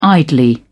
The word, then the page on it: idly: British English